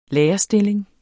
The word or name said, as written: lærerstilling